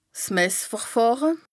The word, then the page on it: 's Messverfàhre: Bas Rhin